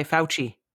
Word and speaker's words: Fauci: But I have noticed that sometimes, so in the States, are they pronouncing it Fauci or Fauci? – No. We are pronouncing it the correct way, Fauci.